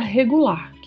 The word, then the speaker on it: regular: Se você estiver mais ou menos, você pode utilizar a palavra regular, que no espanhol é pronunciada com um R um pouco mais marcado que no português. Regular.